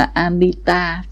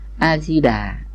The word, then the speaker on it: Amita: Mà Amita chứ không phải A-di-đà, người Tàu đã đọc là Amita Phật.